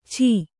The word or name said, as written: ಛ